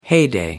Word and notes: heyday: American English

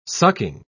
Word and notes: sucking: British English

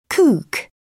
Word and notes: kook: British English

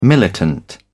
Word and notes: militant: British English

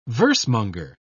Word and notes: versemonger: British English

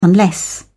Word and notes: unless: British English

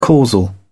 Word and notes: causal: British English

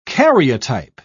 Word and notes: karyotype: British English

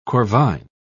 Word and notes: corvine: British English

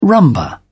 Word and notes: rumba: British English

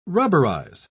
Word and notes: rubberize: British English

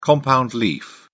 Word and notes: compound leaf: British English

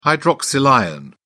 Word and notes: hydroxyl ion: British English

